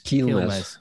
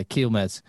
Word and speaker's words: Quilmes: Quilmes. – Yeah, Quilmes.